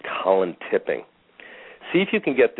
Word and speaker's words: Tipping: His name is Colin Tipping. Last name is spelled T-I-P-P-I-N-G, like you would a waiter, Tipping. – Okay, I got it. Yes, awesome. – First name, Colin, C-O-L-L-I-N.